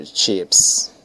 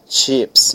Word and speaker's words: chips: Potato chips, olha a pronúncia, chips, não é chip, é chips. Então lá chip é com S, chip seria navio, mas aqui é chip, ok? No plural, chips.